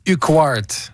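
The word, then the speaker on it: Urquhart: Là, on y avait préféré, j'ai de la misère à prononcer le nom du joueur, Uquart, comment on le dit? Corey Uquart. – Ouais, OK. J'accepte cette prononciation.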